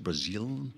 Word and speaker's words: Brazile: Is that how it's pronounced? Brazile? Yes. Yeah.